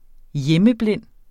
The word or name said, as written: hjemmeblind